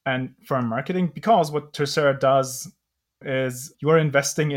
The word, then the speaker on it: Tercera: Michelle is a partner at Terracera. Did I pronounce that correctly? Michelle, you can correct me. – You did, yep. Spanish for third.